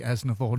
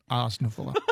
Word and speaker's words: Aznavour: That would be Aznavour, not Aznavour.